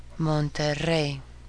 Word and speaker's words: Monterrey: Monterrey.